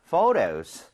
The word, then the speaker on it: photo: 그리고 참고로 ph는 f와 성질이 비슷하기 때문에 발음할 때 포토가 아니고요. photo 이렇게 부드럽게 해 주셔야 돼요.